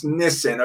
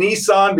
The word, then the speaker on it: Nisan: But in Nisan, right here, the Nisan, they say it's pronounced Nisan, okay? Some like saying Nissan because it got so famous because of the car, but there's only one S in here, okay?